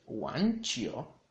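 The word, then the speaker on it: wan chio (网球): Tenis en chino se dice wan chio, wan chio, wan chio, con un tercer tono la lado de wan y un segundo tono en la u de chio, wan chio, wan chio.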